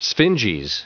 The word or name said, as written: sphinges